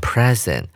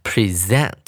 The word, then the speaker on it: present: 当做名词使用的时候，念成 present，它是一个礼物的概念。当动词的时候呢，重音节移到第二音节，我们要念成 present，它是呈现啊、展出的概念，show 的概念，它呈现了。